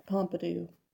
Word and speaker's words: Pompidou: I was saying that wrong. – We went to the Pompidou. – The Pompidou Center.